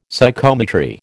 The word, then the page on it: Psychometry: American English